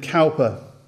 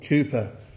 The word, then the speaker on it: Cowper: William Cowper, or Cooper, however you pronounce his surname, depending on which part of the country you come from, the famous hymn writer and poet, he knew what it was to experience dark times of depression.